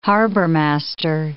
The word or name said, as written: harbormaster